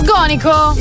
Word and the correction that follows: Sgonico: In realtà l'accento è diverso, è Sgonico. – Sgonico.